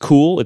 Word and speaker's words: Kühl: I mentioned how a lot of people had recommended pants by a brand that I think is pronounced Kuhl. It's K-U-H-L, and the U has the two dots over.